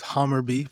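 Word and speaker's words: Hammarby: Well, it's Hammerby, but yeah, Hammerby.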